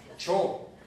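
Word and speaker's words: ĉ: Cho, o sea, lo que en español para nosotros es la C con circunflejo se pronuncia cho, como chocolate. Es corta y fuerte, ¿no?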